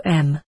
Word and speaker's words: Om: The pronunciation is Om.